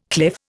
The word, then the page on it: cliff: Indian